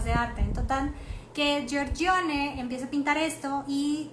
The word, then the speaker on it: Giorgione: Ahí está, esta Dresden de Venus que fue pintada en 1510 al 11, pero este fue por el que no puedo pronunciar, Miren, ahí está, Giorgione, Giorgione, con el acento italiano.